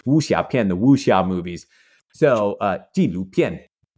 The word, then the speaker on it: 纪录片: Well, I'll just teach us how to say documentary. – Okay. – Real easy. So, 纪录片. – How do you say that? – 纪录片. – 纪录片. – No. So, J-I-G. – 纪录. – 录片. – 片. – It's they're all the fun. Yep, exactly. So, 片 is the word that you put at the end of any sort of genre.